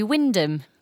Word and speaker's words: Wymondham: It's actually Wyndham. Wyndham in Norfolk. – Like Wyndham Earl. – Yeah, exactly. But the thing was, it looked like it should have at least three syllables when you look at the word written down. How was I possibly to know it was Wyndham?